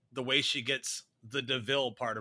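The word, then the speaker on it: de Vil: It's devil, but it's pronounced DeVille.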